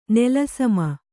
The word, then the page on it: ನೆಲಸಮ: nela sama